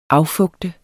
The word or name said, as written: affugte